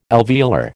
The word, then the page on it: alveolar: American English